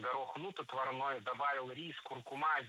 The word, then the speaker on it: куркума: А в словарях, рекомендованных Министерством просвещения, норма куркума без вариантов. Так что следует придерживаться пока такого ударения – куркума.